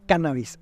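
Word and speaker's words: cánnabis: Cannabis, en realidad, con acento en la primera A, cannabis.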